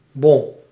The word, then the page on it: bon: /bɔn/